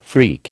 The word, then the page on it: freak: American English